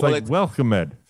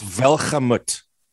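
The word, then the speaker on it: Welgemoed: It looks like it's spelled Welgemood. – Yeah. – But it's Welcome-ed. – All right. It's Welchemut.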